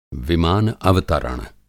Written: विमान-अवतरण

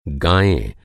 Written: गाय

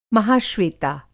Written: महाश्वेता

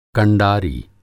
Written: कंडारी

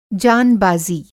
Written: जान बाज़ी

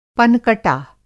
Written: पनकटा

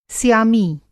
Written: स्यामी

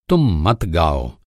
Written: मत

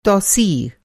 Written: तौसीअ

